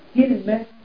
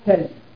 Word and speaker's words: كلمة: تقال كلمة وتقال كلمة وتقال كلمة. فكلمة على وزن ماذا؟ على وزن نبقة وهي لغة أهل الحجاز. وكلمة على وزن سدرة. وكلمة على وزن تمرة.